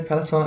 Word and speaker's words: Peloton: Okay, so are we allowed to say Peloton? I mean, it rhymes with reggaeton.